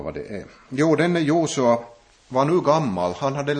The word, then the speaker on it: Josua: Och när Mose dog, så fortsatte Josua, eller egentligen jag ska säga att det här, vi brukar här i vår finländska tradition uttala det här lite fel. Det här namnet i Sverige säger man Josua, och det är närmare Jehoshua kanske man skulle säga, om man skulle säga det på riktigt.